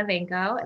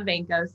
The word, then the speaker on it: Vanko: My Twitter handle is Christina Vanko, and that's CRISTINA. Everybody spells my name wrong. And well, I guess I should spell the whole thing out. Christina Vanko, CRISTINA VANKO.